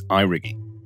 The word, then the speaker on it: Eirigi: Now I didn't know how to pronounce it initially, but apparently it's Eirigi, which is Oshgeilga for We Rise Together.